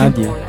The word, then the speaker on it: Nadie: Nadie mesmo que se pronuncia? – Nadie.